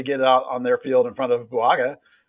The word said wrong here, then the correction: Buanga: I mean, you know, Buanga, excuse me, I can say that correctly, but, you know.